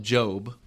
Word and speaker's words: Gob: They call him Job, which usually is spelled J-O-B, but his name is George Oscar Bluth, so he writes his name G-O-B, and everybody calls him Gob.